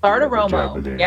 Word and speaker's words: Bartiromo: Bartiromo.